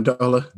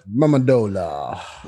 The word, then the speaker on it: Amendola: Danny, Danny Amendola. – Amendola.